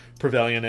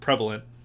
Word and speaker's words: prevalent: older figurines and Knickerbocker Mickey and gang stuff that, you know, was prevalent in the 60s and 70s. And prevalent? – Prevalent. Sorry. Again, mispronounced words.